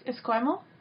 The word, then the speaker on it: Esquimalt: Esquimalt? – Oh, Esquimalt? – Yes, that's it.